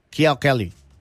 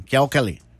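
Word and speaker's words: Kiao Kelly (巧克力): Y en chino, me dice Katia Sanchun, se pronuncia Kiao Kelly. Así está bien, Kiao Kelly.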